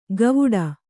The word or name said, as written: ಗವುಡ